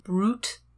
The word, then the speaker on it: bruit: Technically pronounced brute, I think. Brute, which is a rumor.